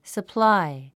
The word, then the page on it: supply: səplái; サプラァイ